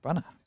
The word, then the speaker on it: Branagh: Branagh. – Branagh, fantastic.